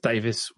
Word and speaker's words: Davies: That's Davis with an IES at the end. – That's it, yeah. – Written Davies, but pronounced Davis.